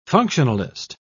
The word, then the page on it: functionalist: British English